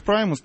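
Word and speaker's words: Primus: Наверное, Праймус, правильно говорить, да? – Примус, как Примус, по-нашему. Да. Примус по-нашему. В общем, их Праймус, наш Примус.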